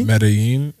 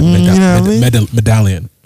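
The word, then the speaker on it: Medellín: Medellin was very interesting because. – Oh, look at you not pronouncing the L as an L. It's a Y. – See, would have said medallion.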